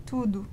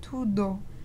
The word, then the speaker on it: tudo: And next, I wanted to talk about the word T-U-D-O, tudo. So I see too many people pronouncing it like tudo, and you think that they would have a different pronunciation because it's two different vowels. But in Brazilian Portuguese, at least, these are, like, this word is pronounced tudo. Another word that would be pronounced like this is canudo, which means a straw.